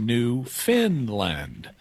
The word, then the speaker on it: Newfoundland: It's spelled New Found Land, but it's Newfoundland. – pronounced New Finland, even though it's not located in Scandinavia.